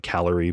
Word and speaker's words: Calor: It's C-A-L-O-R. It looks like calorie, but I called it Calor. I found out later, Calor is the way you pronounce it.